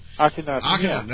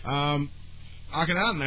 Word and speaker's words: Akhenaten: I always mispronounce the name. – Akhenaten. – I do it. Okay, I got it right finally after like 10 years. Akhenaten.